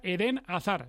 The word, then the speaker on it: Hazard: Que, por cierto, hemos preguntado a fuentes bien informadas, se pronuncia Eden Hazard. Esto sería Hazard. Un poco castizo, bueno. Porque hay que meterle un poquito la R francófona y demás, pero Eden Hazard, es decir, que hay que acostumbrarse a no pronunciar Hazard, como solemos decir la mayoría de periodistas y de aficionados.